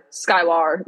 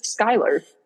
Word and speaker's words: Skylar: Like the hard, like the A-R. It's because my name is spelled that way that so many people do that. And it's like, no, it's just Skylar. You don't, like, I know it's not spelled with the E-R, but you don't have to say Skylar.